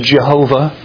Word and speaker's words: Yahweh: And an English pronunciation, you may have heard this, Jehovah. But the Hebrew pronunciation, thanks to the Masoretes for about 1,000 years after the coming of Christ, who put vowel points in there for us so we know how to pronounce these Hebrew words. The Hebrew pronunciation is Yahweh.